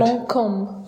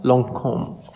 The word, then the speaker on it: Lancôme: Lancôme. – Lancôme. – Yes, Lancôme.